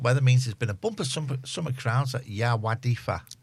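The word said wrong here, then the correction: Yr Wyddfa: Is that how you pronounce it? – Erwidfa. – Thank you. Thank you to our Welsh experts.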